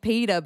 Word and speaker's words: Peeta: There's a guy in it called Peter, but it's spelled P-E-E-T-A.